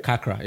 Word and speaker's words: Kakra: Kakra, yeah. – Kakra. – K-A-K-R-A.